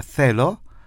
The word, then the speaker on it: θέλω: La palabra θέλω se pronuncia con la lengua entre medio los dientes.